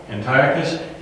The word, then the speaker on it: Antiochus: And I'm reading this going, oh, it's just because we emphasized the wrong syllable. I mean, you read the word Antioch, and that's how we pronounce it, Antioch, because we have the word anti or anti. But when we put this on it, we pronounce it differently. We pronounce it Antiochus.